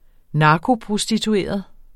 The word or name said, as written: narkoprostitueret